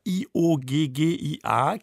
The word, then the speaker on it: Chioggia: Das kleine Venedig, Chioggia, das sollten wir uns deutschen Hörerinnen und Hörern sagen, geschrieben Chioggia, Chioggia, genau.